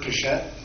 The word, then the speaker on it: Pichette: My name is Edward Pichette. Last name is spelled P-I-C-H-E-T-T-E.